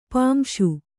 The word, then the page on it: ಪಾಂಸು: kannada